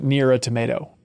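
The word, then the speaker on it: NieR: Automata: Just yesterday, my wife wanted to get Nier Automata. – That's how it's pronounced. I'm sorry, J.V. Gwaltney pronounced it Nier Automata, and so that's actually the canonical way.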